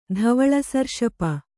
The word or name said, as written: ಧವಳಸರ್ಷಪ